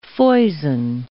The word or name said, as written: foison